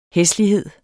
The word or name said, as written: hæslighed